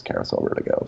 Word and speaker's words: Carousel Vertigo: And it's also, in multiple languages, it's pronounced the same, and it's everyone says carousel vertigo.